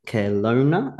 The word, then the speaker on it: Kelowna: Keith is a civil engineer, technologist, and vice president of a heavy civil construction company in Kelowna.